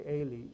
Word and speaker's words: Eli: Well, let's talk about Eli, or Eli, depending on how you want to pronounce it. But it's actually Eli in Hebrew.